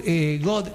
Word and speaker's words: Gödel: A Kurt Gödel, se escribe Gödel con dos puntitos arriba del lado, quiere decir que Gödel en alemán, ¿no?